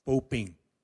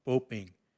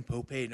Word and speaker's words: Popay: she would always cringe because she would say in Tewa, his name was pronounced Poping, Poping. And she would always, every time she'd see it written, Popay, no, that's not the way it's pronounced.